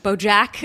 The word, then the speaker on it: BoJack: BoJack, BoJack, BoJack. – If that's the way, BoJack Horseman. no, you're pronouncing it right.